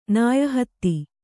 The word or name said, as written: ನಾಯಹತ್ತಿ